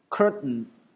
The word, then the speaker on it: curtain: 你看这个单词的构成 curtain， t 中间加了一个元音字母组合 ai，后面加了一个 n，这种组合方式那个 t 是不发音的，直接用鼻音把那个 n 的 n 出来，所以读成 curtain curtain curtain curtain。